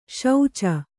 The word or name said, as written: ಶುಚಿ